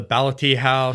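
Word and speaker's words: Ballathie House: Yeah, that's a pretty good pronunciation. The Ballathee House.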